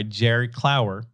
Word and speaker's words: Jerry Clower: We're talking about More Goodins by Jerry Clower, released in 1981.